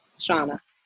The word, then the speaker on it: Shauna: yeah, so everybody always says Shauna, Shana, something like that. They always mix it up. So yes, Shauna.